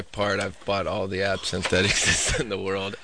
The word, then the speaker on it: absinthe: How do you say that? Absinthe? – Yeah, it's absinthe.